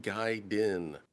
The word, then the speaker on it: Gaiden: You put the emphasis on Guy in Gaiden, like Gaiden.